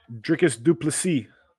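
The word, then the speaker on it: Dricus du Plessis: Dricus Duplessis.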